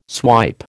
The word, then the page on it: swipe: American English